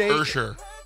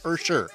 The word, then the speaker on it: Usher: It's pronounced Usher. – Yeah, Usher.